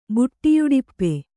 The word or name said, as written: ಬುಟ್ಟಿಯುಡಿಪ್ಪೆ